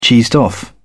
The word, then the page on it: cheesed off: British English